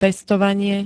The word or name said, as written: pestovanie